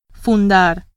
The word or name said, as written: fundar